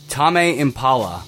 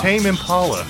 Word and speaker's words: Tame Impala: Okay, this is Remember Me by Tame Impala. – Oh, I remember this. It was the song that deafened me. – How did you pronounce it? – Tame Impala. – It's Tame Impala.